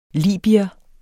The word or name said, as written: libyer